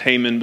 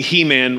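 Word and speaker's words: Heman: So, uh, I'm sure it's actually pronounced Haman, but to me, He Man wrote Psalm 88, and I'm sticking to it.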